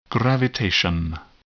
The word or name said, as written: gravitation